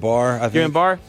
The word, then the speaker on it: Guillain-Barré: Is it Guillain-Barré syndrome? – Guillain-Barré.